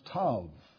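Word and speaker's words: Tav (תו): The first letter of the word, and remember it's a Hebrew word, is what we would call T, but in the Hebrew language it is pronounced Tav, and it's spelled T-A-W, but the W is pronounced like a V, so it is actually pronounced Tav.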